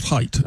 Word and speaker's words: Haidt: Oh, it's spelled not like it's pronounced. It's Haidt. – Haidt. – Yeah, and he pronounces it Haidt.